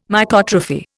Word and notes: Mycotrophy: Indian

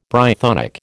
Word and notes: Brythonic: American English

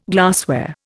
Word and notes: glassware: Indian

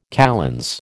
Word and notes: kalends: American English

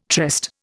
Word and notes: tryst: Indian

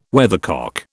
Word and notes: weathercock: British English

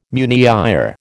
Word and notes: meunière: American English

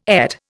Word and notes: ET: Indian

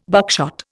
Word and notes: buckshot: Indian

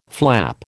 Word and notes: flap: American English

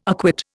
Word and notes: acquit: Indian

